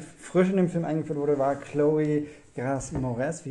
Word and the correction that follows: Grace: Also Gras bestimmt nicht. – Chloe, Chloe Grass. – Chloe Grace, Grace Moretz, würde ich sagen.